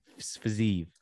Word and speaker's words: Fiziev: Rafael Fiziev. – Fiziev. Is that how you say it? – I say Rafael Fiziev. – Fiziev. – Like Kiev. Fiziev.